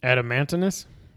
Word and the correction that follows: Adamantoise: Close. You got an extra syllable in there. It's Adamantoise.